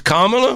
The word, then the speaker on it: Kamala: It's Kamala? – Yeah. Like, Kamala. – Whoopi Goldberg says Kamala. – Huh? – Whoopi Goldberg says Kamala.